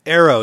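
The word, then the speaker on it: Aereo: Arrow, is that how you pronounce it? The company that just actually was shut down by Arrow, yeah.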